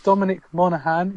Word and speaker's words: Dominic Monaghan: Quantum Break casts Lost Dominic Monaghan.